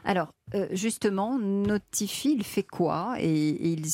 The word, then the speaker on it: Notiphy: Jusqu'à que moi et Benjamin Bois, mon associé, on n'avait pas eu l'idée justement de créer le système Notify. Donc un système qui permet de notifier la présence des pesticides à la parcelle. – Notify qui s'écrit N-O-T-I-P-H-Y, évidemment.